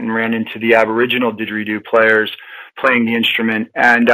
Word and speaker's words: didgeridoo: And am am I pronouncing it correctly? – You are. It's uh, in it's didgeridoo is a uh, it's actually it's an onomatopoeia.